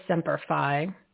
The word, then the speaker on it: Semper Fi: Semper Fi.